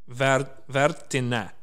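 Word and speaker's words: Värttinä: No, I got my phonetics out, so it's Vartina. The A is like a from cat.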